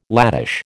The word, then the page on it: latish: American English